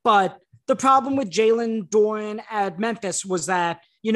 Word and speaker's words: Duren: They traded number thirteen, where that was the Jalen Duren pick, and I actually would have loved Jalen Duren Doran, Duran. I'm not, it's spelled like Dur- Duran, but it's pronounced Doran.